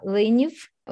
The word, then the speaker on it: Liniv: Wie sage ich es eigentlich richtig? Lüniv oder Liniv? – ist kein Problem, weil in Ukrainisch klingt sowieso Oksana Leniv und sie haben ja fast nicht äh, diese harte L, sondern L immer.